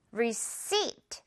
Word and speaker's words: receipt: p는 유령이라서요. 지금 여러분 발음 나지 않습니다. receipt 그냥 무시하고 p는 없다고 생각하고 발음을 해주세요. receipt. seat 뒤에 부분 강세 주면서요.